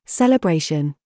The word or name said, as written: BOB